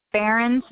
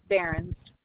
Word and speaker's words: Berens: Behrens, B-E-R-E-N-S. – B-E-R-E-N-S? – E-N, yes. – Did I spell it correctly? – B as in boy, E-R-E-N-S, Behrens.